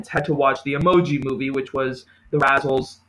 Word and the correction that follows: Razzies: It's called the Razzles, right? I said that right? – The Razzies. – The Razzies.